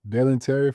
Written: Dalen Terry

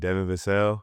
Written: Devin Vassell

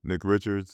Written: Nick Richards

